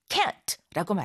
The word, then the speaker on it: can't: cannot 해서 can't라고 말하게 됩니다. 발음할 때 모음을 약간 길게 해서 강하게 can't, can't라고 하고요. 실제로 할 수 있다라고 할 적엔 근 이렇게 약하게 거의 뭐 큰 정도로밖에 발음이 안 될 경우도 있어요.